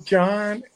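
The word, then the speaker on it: Jon: To John and Katie.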